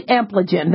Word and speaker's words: Ampligen: The proposed brand name is Ampligen, or Ampligen, probably Ampligen. They always make it a soft G.